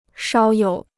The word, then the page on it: 少有: shǎo yǒu